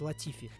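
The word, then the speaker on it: Latifi: Так вот, начиналось-то все на самом деле не так уж погано-то для дебютанта будущего сезона в команде Вильямс э, Николя Летифи. – Давай называют только не ну, Николя, ну, Николас, Николас, да, он да. все-таки Николас, и он даже, по-моему, произносит Латифи.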